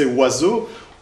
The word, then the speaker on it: oiseau: De ce point de vue-là, euh, vous connaissez, hein, on le cite plutôt pour vous amuser euh, que pour autre chose, le fameux cas du français oiseau, où aucune des lettres n'est prononcée telle qu'elle devrait l'être, d'accord Puisque, pardon, hop, j'y reviens, O et I se prononcent oa. Euh, le S est en fait un Z quand il est euh, euh, entre deux, phonogrammes qui renvoient à des voyelles.